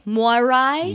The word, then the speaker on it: Moirai: It's spelled M-O-I-R-A-I, Moirai.